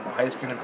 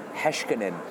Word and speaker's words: Heiskanen: And Heiskanen played a really good game one, I think. – It's actually pronounced Heiskanen.